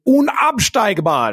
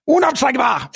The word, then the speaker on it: unabsteigbar: Det var perfekt uttal. Unabsteigbar! Unabsteigbar, precis.